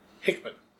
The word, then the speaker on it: Hickman: Is it Hickman or Hickman? – Hickman. – Hickman. – Yes. – But it's spelled man. – M-A-N. Yeah. – So Hickman. – H-I-C-K-M-A-N.